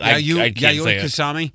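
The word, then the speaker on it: Yayoi Kusama: Yayoi Kusami? – Mm-hmm.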